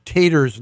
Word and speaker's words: Tatar: I mispronounced Mr. Tater's name a few minutes ago. It's pronounced Tater, T A T A R.